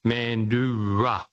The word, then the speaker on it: Mandurah: It's an indigenous name, and it's pronounced Mandura.